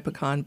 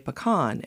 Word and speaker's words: pecan: How do you pronounce the word that's spelled P-E-C-A-N? – It depends on where your parents are from. Okay. So in my family, as I wrote in my pecan book, I could never pronounce it right because my mother was from Atlanta and urban people tend to say pecan.